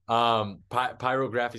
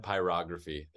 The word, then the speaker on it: Pyrography: It's called Pyrography. I'm messing that up again. – you're putting the wrong emphasis on the wrong syllable is what you're doing. Pyrography is fine. Pyrography.